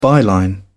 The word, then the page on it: byline: British English